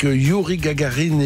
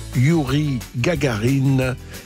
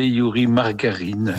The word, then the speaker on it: Gagarin: Au début, je l'ai prononcé Yuri Margarine. – Enfin. – Voilà, je connaissais la margarine, pas la Gagarine.